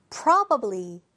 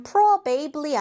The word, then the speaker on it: probably: probably. 자, 이거 발음 probably 아닙니다. probably. 먼저 한번 발음해주세요.